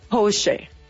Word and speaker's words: Porsche: Y solo como un dato cultural, déjenme les cuento que es alemana y su correcta pronunciación es, escuchen, Porsche.